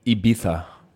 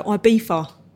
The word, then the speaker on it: Ibiza: Most people just go, Ibiza. – right? – We say Ibiza. – You say Ibiza. – Like a z. – Ibiza. Yeah. – Like a pizza.